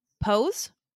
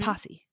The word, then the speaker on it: Pose: Would you pronounce Pose or like Posse? Pose. – Pose. – What's Posse's two S's. P-O-S-S-E.